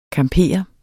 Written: camper

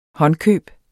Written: håndkøb

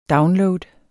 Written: download

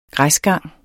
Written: græsgang

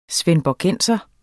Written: svendborgenser

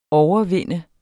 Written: overvinde

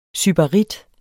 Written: SYBARITTER

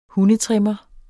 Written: hundetrimmer

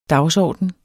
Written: dagsorden

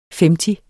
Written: femti

